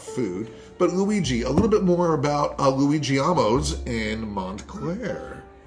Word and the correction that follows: Luigino's: It's pronounced Luigino's, which in Italian is Little Luigi.